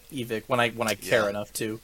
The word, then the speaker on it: Evike: you can all get all this from Evic. I was like, oh shit, we have been saying it wrong. So I tried to switch, but it sounds so weird. – Yeah, saying Evic.